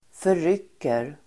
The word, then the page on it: förrycker: för'yk:er